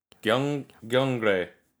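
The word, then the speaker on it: Gyeongre (경례): Gyeongre. That's really hard for me because it's an R and Ye combined.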